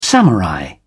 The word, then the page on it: samurai: British English